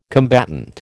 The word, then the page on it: combatant: American English